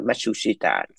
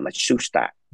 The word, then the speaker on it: Matsushita: Matsushita là bên bên Mỹ nó gọi Matsushita for cái syllables, nhưng mà tôi Nhật nó gọi là Matsushita, có ba thôi.